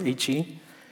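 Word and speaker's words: he (ה): If you see it in your Bible, it's H-E. But no, it's pronounced he.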